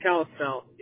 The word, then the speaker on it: Kalispell: And I see that Kalispell, am I saying that correctly? – Yep, Kalispell. Yep.